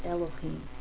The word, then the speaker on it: Elohim: It is actually kind of an unusual thing because it is Yud Hey Vav Hey, but when we see it, it has the vowel points to tell us not to say Adonai, as we normally do, but to pronounce it Elohim.